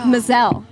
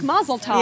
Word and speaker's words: Maazel: It rhymes with gazelle. – Oh. Maazel. – You will never forget that now. – But I Maazel. Thought Maazel like Mazel Tov.